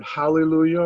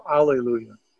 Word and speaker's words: hallelujah: We say, I told you about the church in St. Louis that split over, you pronounce the word hallelujah or alleluia, H or A as your beginning letter of that word, and literally split a congregation in two.